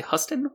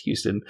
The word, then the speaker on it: Huston: It starred Raul Julia as Gomez Addams, Angelica Houston as Morticia Addams, and I always want to say Huston when I see it written, even though I know it is pronounced Houston.